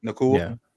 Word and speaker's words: Nacua: Did I say it right? Puka Nacua? – Yeah, Nacua.